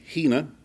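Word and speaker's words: hina (ἵνα): But the word "that" in the Greek is I-N-A, pronounced with an H, hina.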